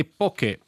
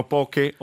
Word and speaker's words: poke: Un altro caso interessante è poke. – Che alcuni dicono poche o poche con l'accento, sì, variabile, no, variabile, sì, l'accento. – L'importante è che poche non è poke, che significa, sì, invece punzecchiare in inglese, sì, poke ha tutta un'altra storia, ma la diremo.